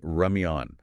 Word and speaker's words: ramyeon: We are talking about noodles, yes, but specifically the romanization is R-A-M-Y-E-O-N. – Yes. – Korean ramyeon. I really want to emphasize that pronunciation for you listening at home. Ramyeon.